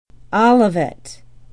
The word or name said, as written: olivet